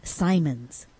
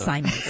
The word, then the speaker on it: Simons: So it's not Simmons. – It's not Simmons. It's Simons. Okay. Simmons, Simons. You say tomato. I say tomato. Well, it's spelt the same way. – No, one M or two M's, guys.